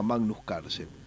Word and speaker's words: Magnus Carlsen: Magnus Carlsen. ¿Lo dije bien, Fede? – Sí, sí, perfectamente.